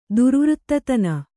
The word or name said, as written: ದುರ್ವೃತ್ತತನ